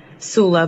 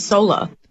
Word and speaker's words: Soula: It's spelled S-O-U-L-A, Sula, but I'd like play on the word. Because a lot of people pronounce it Sola.